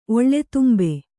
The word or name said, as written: ಒಳ್ಳೆತುಂಬೆ